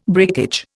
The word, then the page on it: breakage: Indian